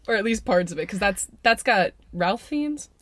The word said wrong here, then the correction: Ralph Fiennes: Rafe Fiennes. But sorry, everybody. I pronounced both portions of your name wrong.